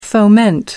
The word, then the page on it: foment: American English